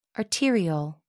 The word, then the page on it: arteriole: American English